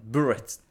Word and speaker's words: bullet: An absolute bullet. – これね、発音が難しいんですけど。 Bullet. 合ってる。 – Close. Bullet. – Bullet. – Yeah, emphasis on the L. L.